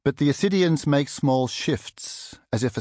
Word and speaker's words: ascidians: Amid the sponges are what look like ferns and flowers, and also ascidians with a silent C, pale pink spout-like structures with enamel patterns inside.